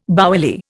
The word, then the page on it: bouilli: Indian